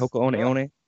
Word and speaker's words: Hoka One One: Hoka One One.